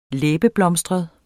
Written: læbeblomstret

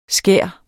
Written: skær

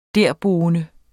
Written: derboende

